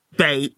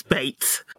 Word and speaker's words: bait: as in like B-A-I-T. I don't pronounce my T's, so, but I say bait, but it's bait.